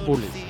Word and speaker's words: Bullys: Se dice Bullies, ya lo sé.